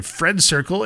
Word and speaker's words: FRND CRCL: Now we're moving over to Friend Circle. It's spelled like FRND CRCL.